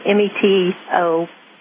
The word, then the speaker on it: metoprolol: One is clonidine, one is hydrochlorot, one is metrolol. I can't pronounce it. Metoprolol. – It's called metoprolol.